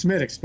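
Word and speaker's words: Smithwick's: As I said, it's pronounced Smittix, but it's spelled Smithwix.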